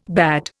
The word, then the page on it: bade: Indian